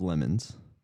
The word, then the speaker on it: LeMons: And so there's this company called the 24 Hour of Lemons. – This is not us pronouncing Le Mans wrong. – No, it is lemons. – Like lemonade.